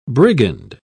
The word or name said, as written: brigand